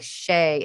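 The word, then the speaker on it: Che: It's C-H-E on, but, I'm not really sure how, I thought it was Shay. – Well, it's her pronoun. Her pronoun is Her pronoun's they, but I'm butchering that. But, like, Shay, it's C-H-E on the show.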